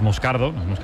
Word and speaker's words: Moscardo: Como bien ha pronunciado Unai, es Moscardo, brasileño, sí, sí.